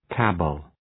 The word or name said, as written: cabal